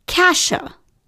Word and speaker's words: Cassia: And then he asked me how I would pronounce the name spelled C-A-S-S-I-A. So I'm thinking, Cassia, and he said, no, it's Kasia.